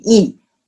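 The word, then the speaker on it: Yi: So the pronunciation called the I, actually in the real Chinese pronunciation should be E. It's like a Y-I-E.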